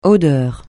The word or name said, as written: odeur